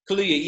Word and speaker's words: Kaluuya: I mean, but it's basically these Daniel Kaluuya. Am I pronouncing that right? – Kaluuya, yeah, who's Kaluuya in Get Out.